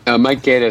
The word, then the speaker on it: Gaeta: Is that how if I pronounce that correctly? – Mike Gaeta is the guy who starts the investigation.